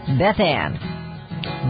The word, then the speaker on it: Beth Ann: We will not get a dime unless you use Beth Ann. Otherwise, it'll go to somebody else. Beth Ann. B-E-T-H-A-N-N is how that is spelled.